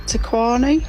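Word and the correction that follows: Te Kanawa: It was Kiri Takanawa.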